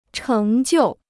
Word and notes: 成就: chéng jiù

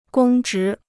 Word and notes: 公职: gōng zhí

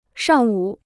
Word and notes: 上午: shàng wǔ

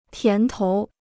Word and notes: 甜头: tián tou

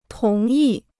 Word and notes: 同意: tóng yì

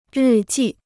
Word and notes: 日记: rì jì

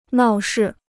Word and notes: 闹事: nào shì

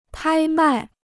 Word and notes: 拍卖: pāi mài

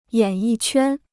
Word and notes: 演艺圈: yǎn yì quān

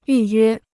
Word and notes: 预约: yù yuē

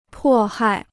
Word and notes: 迫害: pò hài

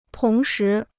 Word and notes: 同时: tóng shí

